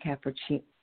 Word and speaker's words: Capparucci: I am talking with Eddie Capparucci.